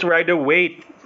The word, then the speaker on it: Rider-Waite: Rider-Waite? I kept on mispronouncing it. So it's Rider-Waite. Okay. – Yes. – All right. – It's the Rider-Waite. – Rider-Waite Smith, right? I mean... – Yep. – Okay. – That is correct.